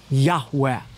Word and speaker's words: Yahweh: In Hebrew, it would be composed of the four Hebrew letters, Yod-Heh-Vav-Heh, which most Semitic scholars believe is pronounced Abrathi Yahweh.